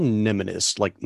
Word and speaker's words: mnemonist: Medical mnemonist, like mnemonics.